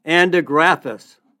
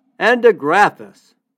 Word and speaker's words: andrographis: The herb is andrographis. Andrographis.